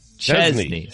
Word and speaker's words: Chesney: Chesney. How do you say it? – Chesney. – So I make it more of like a Z.